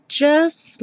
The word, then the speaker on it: just: Just like. O T do just não é pronunciado, então o S já vai direto para o L.